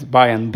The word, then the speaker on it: Bayan: But can you explain the difference between a Bayan? Am I pronouncing that correctly? – Yeah, it's Bayan, B-A-Y-A-N.